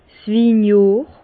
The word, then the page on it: 's Winjohr: Bas Rhin; Herrlisheim